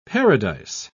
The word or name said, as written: paradise